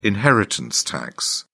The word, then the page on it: inheritance tax: British English